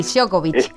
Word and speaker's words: Djokovic: O lo tenés, yo que sé, al tenista número 1, Djokovic, o algo así, no me acuerdo bien cómo se pronuncia. – Sí, Djokovic.